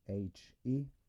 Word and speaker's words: Nietzsche: You spell that, his first name Friedrich is Friedrich, and his last name is N-I-E-T-Z-S-C-H. Nietzsche.